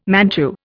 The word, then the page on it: Manchu: Indian